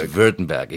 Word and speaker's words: Württemberg: It is Württemberg. It looks like Württemberg when you look at it, but it's a Württemberg. – It's Württemberg, exactly.